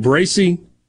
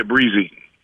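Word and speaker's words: Bresee: As you've looked a little bit at, and I'm going to mispronounce his last name, is it Bracy? – Or Breezy. Breezy. Okay. Yeah, Breezy.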